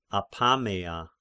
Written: Apameia

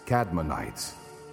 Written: Kadmonites

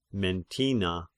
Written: Mentina

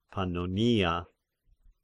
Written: Pannonia

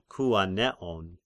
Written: Kyanean

